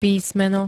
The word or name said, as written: písmeno